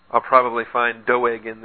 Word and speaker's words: Doeg: Except sometimes you get some real weird ones like Doeg the Edomite in 1 Samuel that the pastor calls Doug occasionally, and that really offends people named Doug. So if your name is Doug, I apologize, but it's probably more accurately to pronounce it Doeg.